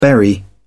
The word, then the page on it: berry: British English